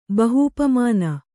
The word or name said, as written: ಬಹೂಪಮಾನ